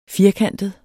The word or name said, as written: firkantet